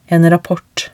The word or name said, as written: en rapport